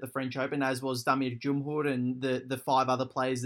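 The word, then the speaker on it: Damir Džumhur: Well, let me just say that was excellent pronunciation of Damir Djumhur. – We have practiced this on the show before, so I'm really anal with my pronunciations. I have to get them right, so I'm fairly, like, I try and make it work when I can. – That's so, well, as an MC, pronunciations are everything, and that is a name I don't hear pronounced correctly often.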